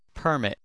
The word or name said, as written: permit